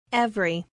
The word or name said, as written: every